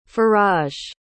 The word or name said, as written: faraj